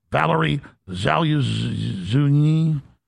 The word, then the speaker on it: Valery Zaluzhny: Valery Zaluzhny.